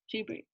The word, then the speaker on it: Ghibli: Ghibli, yeah.